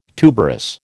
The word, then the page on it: tuberous: American English